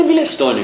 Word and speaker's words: Milestone: O Milestone é Milestone mesmo, se escreve, né? Mas lá ele pronuncia Milestone.